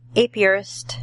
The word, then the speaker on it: apiarist: Well, Donald, I think you're going to enjoy our first segment of the show with state apiarist.